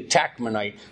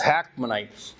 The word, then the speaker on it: Hakmonite: If you look into different places, it's pronounced with a T, a Takmonite. But for here, it's Hakmonite.